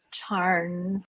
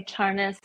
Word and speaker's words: Charnes: Well, there is no the American pronunciation is charns, but I have other relatives that call it that say charnas.